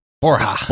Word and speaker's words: Borja: His legal prowess continued to garner him high esteem in the courts, and he officially changed his name to Borgia from the Spanish pronunciation that I'm going to butcher Borja. Borja. Borja. Okay.